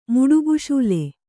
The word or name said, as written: ಮುಡುಬುಶೂಲೆ